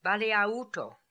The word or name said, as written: Baleaooto